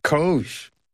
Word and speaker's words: COGE: How do you pronounce the acronym for this commission? – COGE. It is exactly what you think it is, Steve. And I know what's in the back of your mind when you ask that question. – Go on. What is in the back of my mind? It does rhyme with Doge.